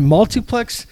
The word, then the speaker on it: MultIPlex: All right, MultIPlex Madness. What do you say we get into it? – Yes, and by the way, just for the listeners at home, that is pronounced with an IP.